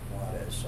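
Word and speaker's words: Juárez: Juarez, or Juarez, but I pronounce it Juarez. I get to know the Spanish pronunciation.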